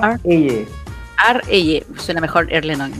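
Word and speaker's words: Erlend Øye: Pero bueno, se llama Erlen Oye, también se pronuncia Ar Eye.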